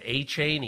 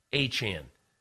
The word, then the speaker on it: Achane: I said Achane. He goes, no, Achane. I said Achane. He said Achane.